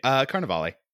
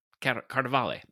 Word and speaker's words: Carnevale: So specifically, when we're talking about this storyline, we are talking about a campaign that was run by Adam Carnevale. Did I pronounce that right? – Carnevale. – Carnevale.